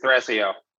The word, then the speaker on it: Thrasio: you pronounce it one word, it's Thrasio.